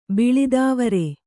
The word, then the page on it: ಬಿಳಿದಾವರೆ: biḷidāvare